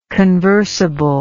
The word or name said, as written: conversable